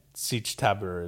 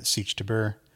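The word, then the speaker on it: Sietch Tabr: Paul tells the audience what everyone else is thinking, Siege Tabor, except he says Tabra, Siege Tabra.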